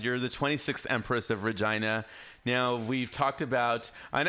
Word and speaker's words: Regina: No, it's Regina, the city that rhymes with fun.